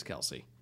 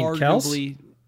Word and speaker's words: Kelce: I know it sounds sacrilegious, but every time I watch Pat Freiermuth, I was like, that's very Travis Kelce. Now, Travis Kelce, one of the greatest tight ends of all time. – You mean Kelce? – Yeah, okay.